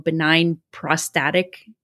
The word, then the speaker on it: benign prostatic: There's one here that I've written down called benign prostatic. Did I say that right? – Yes.